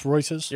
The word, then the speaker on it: Reuters: And is it Reuters? Is that how you pronounce it? – It is pronounced Reuters.